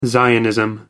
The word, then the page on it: zionism: American English